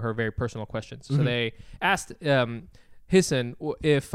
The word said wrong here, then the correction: Hasan: Hisson? I think it's Hasan.